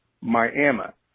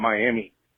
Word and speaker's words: Miami: In Oklahoma, many of our towns are Indian pronunciations, and it's pronounced Miami. And so if you talk to anyone from that town, they will definitely tell you it's Miami and not Miami.